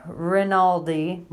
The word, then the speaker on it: Rinaldi: Randini, how am I pronouncing that? I feel like I get it wrong. – Jordan Rinaldi versus Alvaro Herrera.